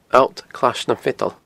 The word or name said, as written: Allt Clais nam Feadan